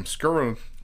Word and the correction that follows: Skowronek: Skowronek. Skowronek, there you go.